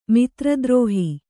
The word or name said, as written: ಮಿತ್ರದ್ರೋಹ